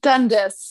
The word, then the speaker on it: Dundas: Dundas. – Dundas. Yeah. – Okay. Actually, the way you say it is correct, but we're American, so we pronounce our own name incorrectly.